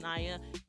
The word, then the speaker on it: Naya: I'm your host, Naya. Make sure you follow me on social media, NIGH_YUH, basically the phonetic way to say my name correctly, black girl problems.